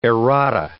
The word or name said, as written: errata